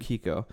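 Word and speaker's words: Keiko: Yeah, and like Kiko? Kaiko? That's his wife's name. – Kiko.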